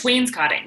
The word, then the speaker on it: wainscoting: It's just wainscoting. That's it. Wainscoting. – Oh, wainscoting.